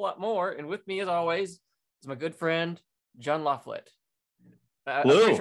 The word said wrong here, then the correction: La Follette: It's La Follette. Rhymes with wallet. – La Follette.